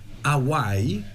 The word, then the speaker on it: AWHY: Hawaii, si pronuncia così? – Esattamente. – Aiutami, è geniale perché si scrive A-W-H-Y, Hawaii.